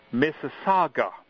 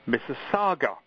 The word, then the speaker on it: Mississauga: With a slight modification about the new suburban Toronto AM station that Fred read about on the December issue, the name of that particular suburb is pronounced Mississauga. Mississauga.